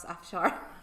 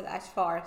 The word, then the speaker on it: Afshar: And my last name, I'll just spell my first and last name, Shookaaf, as in flower, Shar. I know it's difficult to pronounce Afshar. Even back home in Iran, my friends always said Afshar, so I totally understand.